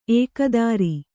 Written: ಏಕೋದರ

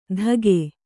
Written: ತಗೆ

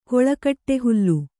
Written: ಕೊಳಕಟ್ಟೆಹುಲ್ಲು

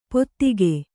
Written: ಪೊತ್ತಿಗೆ

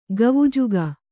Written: ಕವುಜಗ

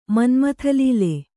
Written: ಮನ್ಮಥಲೀಲೆ

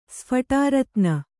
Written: ಸ್ಫಟಾರತ್ನ

